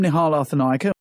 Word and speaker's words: Nihal Arthanayake: It was mostly due to the DJs Bobby Friction and Nihal Arthanayake. I probably butchered that as well. So here's how it's actually said. – I'm Nihal Arthanayake.